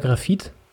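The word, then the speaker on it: Grafite: Ja, es gibt, du kannst beides irgendwie, also er selber sagt wohl eher Grafit oder Grafit.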